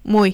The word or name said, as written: muy